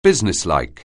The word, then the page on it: businesslike: anglais